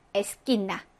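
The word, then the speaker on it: esquina: 이 발음은 개, 기와 마찬가지로, 우 발음하지 않아서 깨, 끼로 발음이 된다라고 했습니다. 그래서, 뭐라고 했죠? 요거? 자켓다 라고 읽어주시면 되고, 이거는 에스킨나 라고 읽어주시면 됩니다.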